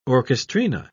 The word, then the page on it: orchestrina: British English